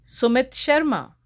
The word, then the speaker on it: Sumit Sharma: Sumit Sharma.